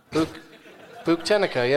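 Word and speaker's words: Buktenica: Buchtenica, yeah, that's how you pronounce it.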